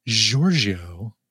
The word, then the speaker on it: Giorgio: I want to make sure I pronounce all this right because it's Giorgio, like the fragrance, Giorgio Moroder.